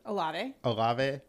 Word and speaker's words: Olave: They did draft Olave, or how do you pronounce that? – Olave.